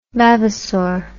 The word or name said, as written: vavassor